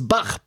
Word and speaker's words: Bach: Parce que oui, une bonne fois pour toutes, ça se prononce Bach, pas Bach.